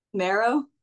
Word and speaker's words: Mehro: Marrow. – Say that again. – His name's Marrow. It's like Marrow. – pronounced like marrow, bone marrow, but spelled MEHRO.